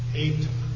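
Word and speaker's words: Eta (ἦτα): Eta. Notice there that that's got the aspirated H. And it is pronounced like an H there.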